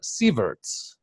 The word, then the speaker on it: Sieverts: We have a special guest today, Lisa Sieverts.